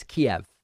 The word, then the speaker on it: Kiev: And when you look at that, that very clearly would make most English speakers say, oh, it's Kiev. That makes sense. It's spelled K-I-E-V and it's pronounced Kiev.